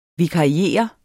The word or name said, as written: vikariere